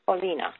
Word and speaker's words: Polina: Polina, okay. – Yeah, Polina. P-O-L-I-N-A.